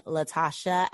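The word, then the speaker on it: Latashá: So you can follow me at Call Me Latasha, LATASHA. And then I spell my name LATASHA with an accent on the last A.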